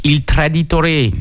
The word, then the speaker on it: Il Traditore: Donc moi j'ai regardé un film qui, sa traduction c'est Le Traître, donc Il Traditore. Alors je sais pas si je le dis bien en italien d'ailleurs. – On valide. Ouais.